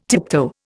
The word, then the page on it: tiptoe: Indian